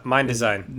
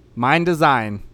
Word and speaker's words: MNDSGN: I listened to Rare Pleasure by, I don't know how to say the name. It's like Minsk. It's like MNDSGN. – Oh, Mind Design. – The name? Mind Design.